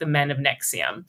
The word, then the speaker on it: NXIVM: If you want to look it up, it's NXIVM, but pronounced Nexium.